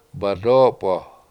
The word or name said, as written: badOpo7